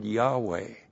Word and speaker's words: Yahweh: Literally, it would be pronounced Yahweh.